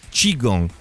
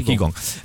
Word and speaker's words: Qigong: Però in realtà c'è un'altra traccia interessante, che è questa del, adesso la pronuncia, di questa disciplina orientale, diciamo così, come si dice, Qigong. – Qigong in italiano. – Ok, Qigong.